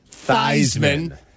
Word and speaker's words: Theismann: At some point, you got to Joe Theismann became Joe Theismann. To rhyme with Heisman.